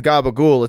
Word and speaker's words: capicola: But the way they pronounce it, I mean, Gabagool is not spelled Gabagool, it's spelled Capicola.